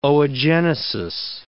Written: oogenesis

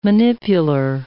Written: manipular

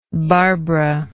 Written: barbara

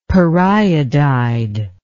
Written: periodide

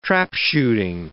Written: trapshooting

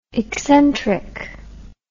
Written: excentric

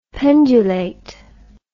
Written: pendulate

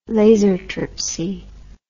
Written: lasertripsy

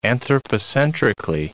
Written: anthropocentrically

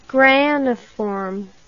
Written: graniform

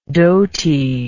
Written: doty

